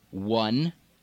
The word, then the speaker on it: one: One, no W in the spelling, but it's the pronunciation, one.